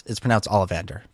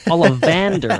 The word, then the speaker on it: Ollivander: And it's pronounced Ollivander. Come on. – Come on. – Ollivander. – Ollivander. I missed, like, a whole syllable.